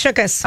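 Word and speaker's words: Shookus: And Ben Affleck officially got together with Lindsay Shukas after... – Shukas. – Oh, is that how you say it? – Yeah. – Is it Shukas? – It's Shukas.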